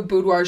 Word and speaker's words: boudoir: She's done so much stuff, but right now her focus is boudoir. Is that how you say it? – Yeah, boudoir.